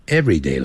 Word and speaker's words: everyday: 每天就是这个样子 everyday life。但是 every 跟 day 分开的时候要念成 everyday，重音在 day 那个地方，